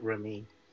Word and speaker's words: Raimi: It's pronounced Raimi. – I thought it was Raimi.